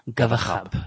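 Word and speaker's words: !Gawaxab: That's Johannes Gavasap. Am I pronouncing that? – Khab. – Gavakhab, yeah.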